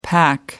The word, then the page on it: pack: American English